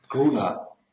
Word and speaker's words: Gruna: Grona heißt er, ne? oder, oder wie wird es ausgesprochen? Gruna. Gruna.